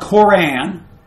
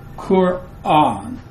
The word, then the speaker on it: Quran: It's not K-O-R-A-N. First of all, there's no O. Right? But and it's two syllables. Well, of course it's two syllables, but Kur is the first one. And then you have an aleph. The glottal stop. And then a long A, Quran.